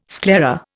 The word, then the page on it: sclera: Indian